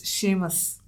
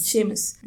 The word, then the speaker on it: Seamus: It's Seamus. – Yeah. – For this episode, let's try to say Seamus. – Seamus.